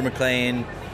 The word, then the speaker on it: MacLean: It's just Andrew McLean, and McLean is spelled M-A-C-L-E-A-N, and that's where I am.